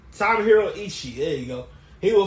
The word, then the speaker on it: Tomohiro Ishii: I can call him Tomario. I can't pronounce the guy's name. – Tomohiro Ishii.